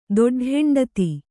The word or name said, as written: ದೊಡ್ಡಹೆಂಡತಿ